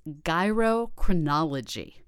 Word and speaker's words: gyrochronology: And one of the factors I loved most was the press release actually said how to pronounce this technique, which is gyrochronology. – I love that term. – It just like a gyroscope.